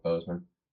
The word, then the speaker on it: Boseman: Yeah, Chadwick Boseman.